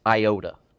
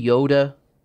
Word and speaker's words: iota: He says, for truly I say to you, until heaven and earth pass away, not even, and you can, we tend to pronounce this iota, all right? It's really Yoda, not Yoda like Yoda from Star Wars, but Yoda, and not a dot.